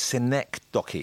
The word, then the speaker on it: synecdoche: I've got to say, I'm just on a personal level, it's synecdoche, isn't it, is the correct pronunciation? That's correct, yeah.